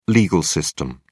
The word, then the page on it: legal system: British English